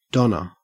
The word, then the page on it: donna: Australian English